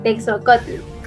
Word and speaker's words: texocotl: Y bueno, por si alguien tiene duda, el origen es náhuatl y se dice te texocotl. Sí, mal no se pronuncia.